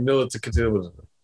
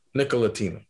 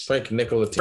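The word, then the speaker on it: Ntilikina: We're not talking about Frank Nikolic. – Say his name. – Nikolatina. – There it is. – Frank Nikolatina.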